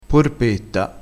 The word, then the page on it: porpetta: dialettale